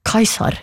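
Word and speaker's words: Caesar: Ordet keiser avledet av tysk kaiser, som igjen avledet av Cæsar, som på klassisk latin ble uttalt omtrent som kaiser.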